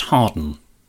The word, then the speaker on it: Hawarden: And also, James Garner is from North Wales, from a village that is spelled H-A-W-A-R-D-E-N, but it's pronounced Harden.